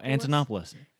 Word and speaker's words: Antonopoulos: Oh, Antonopoulos.